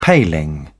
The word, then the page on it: paling: British English